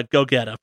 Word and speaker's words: Gogeta: So, like, do you call him Gogeta? Like, how would you pronounce it out there in Boston? – Uh, Gogeta, probably.